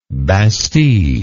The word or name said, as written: bastide